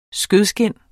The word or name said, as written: skødeskind